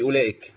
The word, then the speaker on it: أولئك: أنا عندي في واو في أولئك، واو بعد الهمزة. – أيوه. – الواو دي مش منطوقة. الواو اللي عليها صفر والسدير دي لا تنطق.